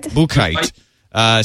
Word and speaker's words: Buchheit: Paul Buchheit? – Buchheit.